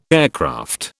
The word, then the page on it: aircraft: British English